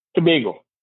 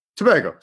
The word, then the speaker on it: Tobago: Is Tobago or Tobago? – Tobago. – Tobago. Sorry, Trinidad and Tobago. And I put the accent on the wrong syllable, I suppose.